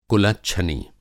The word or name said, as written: कुलच्छनी